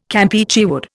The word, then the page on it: Campeachy wood: Indian